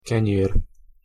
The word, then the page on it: kenyer: Hungarian